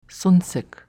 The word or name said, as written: sunsick